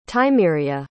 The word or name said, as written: tymeria